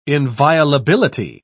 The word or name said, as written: inviolability